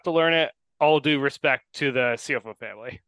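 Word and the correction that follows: Ciuffo: Baseball reference tells me that it's Schufo, Nick Schufo, which I guess makes more sense.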